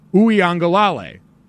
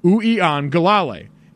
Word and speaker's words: Uiagalelei: So for everyone keeping score at home, we have, from California, DJ Uiagalelei. Uiagalelei.